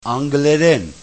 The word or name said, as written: Angleren